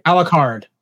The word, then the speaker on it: Alucard: Okay, so your band, Steven Tyler, Eddie Van Halen, Joe Satriani, Rick Savage, Peter Criss, Arsenio Hall, and you're called Aculard. No, am I saying that right? – Alucard. – Alucard, sorry. Alucard. Dracula backwards.